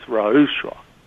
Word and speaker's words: ruach: The word for spirit is Ra'ushua, and it's spelled R-U-A-C-H.